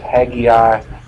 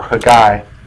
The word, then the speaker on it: Haggai: A book called, depends on how you want to pronounce it, Haggai or Haggai. I think it's the way the Jews would pronounce it, Haggai.